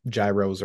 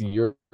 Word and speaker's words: gyro: Yeah, is that how it's pronounced? – I think it's euro. – Oh, it's euro. Okay, we'll have a euro or something.